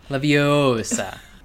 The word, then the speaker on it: Leviosa: First one, uh, with respect to, and correct me if I'm wrong about my pronunciation, uh, Wingardium Leviosa. – Mm-hmm. – Uh, I feel like you need what, a longer degree... – O. Leviosa.